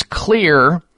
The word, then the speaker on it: Xlear: Well, that is Clear Max Nasal Spray. The Clear is spelled X-L-E-A-R.